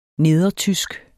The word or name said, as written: nedertysk